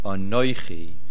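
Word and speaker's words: anoichi (אָנֹכִי): The main accent is on the nun, since this word comes at an esnachta, at a main hefsek, anoichi.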